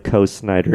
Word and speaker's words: Coe Snyder: I should probably just say if you want to find me on the internet, just type Co Snyder into any social media platform.